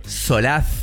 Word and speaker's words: Solaz: Solaz. – Viste que Sorbo pronuncia la Z como nada. No la están pronunciando muy bien. Porque primero es con S. Solaz.